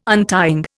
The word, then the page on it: untying: Indian